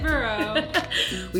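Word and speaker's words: Bailieboro: Baileyboro. We talked about how that's like Bailey's the alcohol, so I can pronounce that right.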